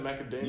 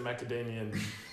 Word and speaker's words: macadamia: Is it macadamia? – You got it. – Macadamia with an M at the end, or is it macadamian? There's no letter at the end.